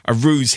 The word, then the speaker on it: Rews: So you're clearly a ruse hipster. It's spelled R-E-W-S. That's right, isn't it? – That's correct.